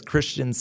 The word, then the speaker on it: Christian: So yeah, it's Christian, C-H-R-I-S-T-I-A-N, and Gianelli, G-I-A.